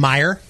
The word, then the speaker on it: Meier: So this beer is called Meyer. It's not spelled like Meyer Lemon, but it's spelled like a guy's name, M-E-I-E-R.